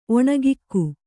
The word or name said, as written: ಒಣಗಿಕ್ಕು